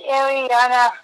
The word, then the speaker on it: Ariana: I was gonna say, did you just call me Rihanna? First of all, I'm trying to say your name correctly. I know. I'm sorry. – My name is Ariana with an A.